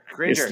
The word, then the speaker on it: Cringer: Cringer. Like, no, Cringer. Don't you dare mispronounce this name.